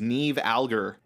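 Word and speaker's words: Niamh Algar: The lead actress, I had to look up how to pronounce her name because she's Irish, so you know how that goes. Her name is Niamh Alger, and she is so good.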